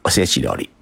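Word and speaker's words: 御節料理: 日本呢念作是おせち料理。御节料理，这个御呢就是御食的御，节呢是节日的节。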